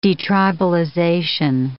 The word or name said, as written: detribalization